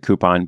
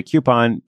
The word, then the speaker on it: coupon: So I pronounce it coupon, but if you say coupon, that's cool too.